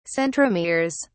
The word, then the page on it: centromeres: British English